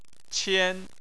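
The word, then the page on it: qian: tone 1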